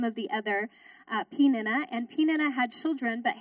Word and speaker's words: Peninnah: I had to look up to make sure I was pronouncing it right. It's Peninnah, his wife, and to all her sons and daughters.